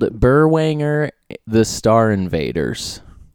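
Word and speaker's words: Berwanger: B-U-R. – It's spelled it's Burr. – B-E-R. – Oh, B-E-R. See, I wouldn't know that. – And then W. – Wanger. – Okay. – It's almost like Wrangler.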